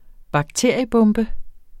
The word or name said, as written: bakteriebombe